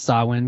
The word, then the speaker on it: Samhain: But no, it's pronounced Samhain because Ireland has its own special rules.